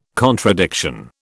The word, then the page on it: contradiction: British English